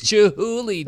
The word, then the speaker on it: Chihuly: She corrected me. I was like, Dale Chihuly? Heck yeah, man. And she was like, it's Chihuly, Dad.